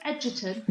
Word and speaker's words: Egerton: Are we going with Edgerton? – Yeah, that's it.